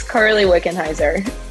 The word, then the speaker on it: Carly Wickenheiser: And before we get started, I did tell you this before, but you're going to have to pronounce your name. I think I said it correctly, but you go ahead. – Yes, you did. It's Carly Wickenheiser. You did a good job.